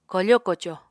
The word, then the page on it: kofo-koco: Apatani